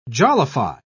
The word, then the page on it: jollify: British English